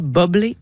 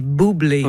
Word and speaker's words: Bublé: Parce que Michael Bublé, on dit pas bubbly, on dit boublé. Mm-hmm. On dit pas bubble non plus.